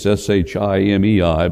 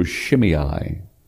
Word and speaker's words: Shimei: If you look at it in English, it's Shimei, but pronunciation in Hebrew, Shimei.